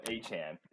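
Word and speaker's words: Achane: I think that's the new pronunciation he wants to go with. Yeah, Achan.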